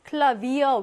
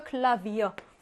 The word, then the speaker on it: Klavier: 근데 이 Faun가 외래어에 올 때는 V로 발음하고요. 외래어의 특징은, 그 위치적인 특징은 뭐냐면 Faun가 맨 앞이나 맨 뒤가 아니라 중간에 있어. 그러면 외래어일 확률이 굉장히 높습니다. Klavier, 외래어거든요. 얘 역시 가운데 있고요. Klavier, V로 발음하죠.